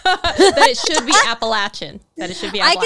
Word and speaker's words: Appalachian: And she had said in an Instagram story, this is where I was following her, she was saying that people miscommonly pronounce Appalachian, and that it's technically Appalachian. And she's like, and it drives us a little batty. And she's like, I know it sounds funky, but if you're over here, people will want you to say Appalachian.